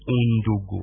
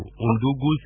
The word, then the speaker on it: Ndungu: Oui, je corrige d'abord votre prononciation, c'est Ndungu. Ndungu, ça signifie...